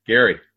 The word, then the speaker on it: Gerry: Gerry.